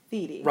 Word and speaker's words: Thede: From It's Thede. Pronounced Thede.